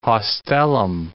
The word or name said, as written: haustellum